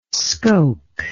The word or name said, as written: scoke